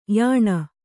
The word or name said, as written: ಯಾನ